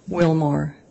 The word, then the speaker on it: Willmore: Cammy, C-A-M-M-Y, Wilmore, W-I-L-L-M-O-R-E.